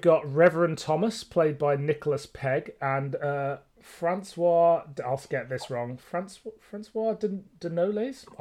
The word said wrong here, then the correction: François de Noailles: I don't know, played by Barnaby Edwards. – Let me have a look. – My pronunciations on glasses this one are really... – François de Noailles. – Okay, I wasn't too far off.